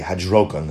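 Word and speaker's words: hidrokon: There are three different types of hidarkon. Or I should say hadrokon, that's really how to pronounce hadrokon.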